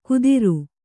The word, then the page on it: ಕುದಿರು: kudiru